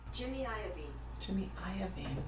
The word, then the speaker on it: Jimmy Iovine: I should make sure that's how you say his name. How do you? I'm not sure. How do you say his name? I feel like I should know this. Here we go. Let's see if this is. – Jimmy Iovine. – No wonder it didn't sound right.